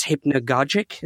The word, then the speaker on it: hypnagogic: And that's or in this state, this hypnagogic, is that how you pronounce it? Yeah.